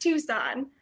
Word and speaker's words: Tucson: But yeah, I grew up in Tucson, so it's pronounced Tucson. – Dude, so I thought that you lived there as well. What? I'm so sorry. – No, it's like right in front of me and I can't read it. Okay. – That's it's funny. Not phonetic. How would you know? It is a weird pronunciation.